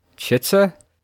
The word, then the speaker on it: Chitsa: In Fallout 2, the pre-made character whose name I'm pretty sure is pronounced Chitsa is the charismatic archetype.